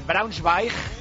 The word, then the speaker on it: Braunschweig: Y el Valle, rival del Real Madrid, está jugando contra el Braunschweig.